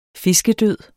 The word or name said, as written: fiskedød